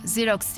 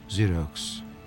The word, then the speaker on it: Xerox: Bu Yunanca. Xerox diye okunur. – Xerox.